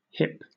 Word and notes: hyp: British English

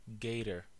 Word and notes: gator: American English

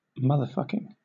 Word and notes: motherfucking: British English

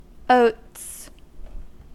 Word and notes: oats: British English

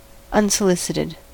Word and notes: unsolicited: American English

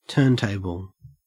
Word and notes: turntable: Australian English

